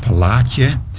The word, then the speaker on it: Palache: It was not a public school, and the name of the school was Palache, P-A-L-A-C-H-E, which was supposed to be pronounced Palatje, but it was named after a Sephardi rabbi.